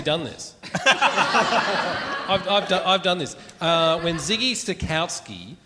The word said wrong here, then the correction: Switkowski: is that how you say his name? – Yeah. – Switkowski.